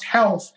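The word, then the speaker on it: Rolls: Then I started formally with my teacher, Ralph Gracie, but it's pronounced health in Portuguese, Brazilian Portuguese. All the R's are H's. Health, Royce, Hickson.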